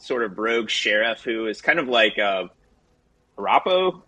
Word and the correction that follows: Arpaio: Is that how you pronounce it? Sheriff Arapo, the sheriff that Trump loved? Joe Arapo. – Oh, yeah, yeah. – I think it was Arpaio. Or Yep, that's right.